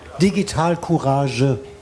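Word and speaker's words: Digitalcourage: You have to, or Digital Courage, as we say in German, you have to pronounce the last E. It's not a silent E.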